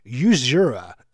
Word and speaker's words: Usura: We played you Two Door Cinema Club, What You Know, then Two Colours, Safri Duo and Christy Sarandi, and Cynical, and then Usura and Open Your Mind. Open Your Mind. But it's spelled U.S.U.R.A. I can imagine so many radio presenters not knowing the name of the song, going, that was U.S.U.R.A.